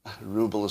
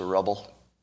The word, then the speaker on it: ruble: and ruble is mispronounced? It's a rubble.